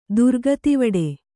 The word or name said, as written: ದುರ್ಗತಿವಡೆ